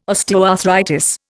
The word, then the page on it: osteoarthritis: Indian